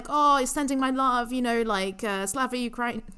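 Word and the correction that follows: Slava Ukraini (Слава Україні): hang on how do you pronounce it I actually wrote this down um, Slava Ukraini you know the the kind of uh,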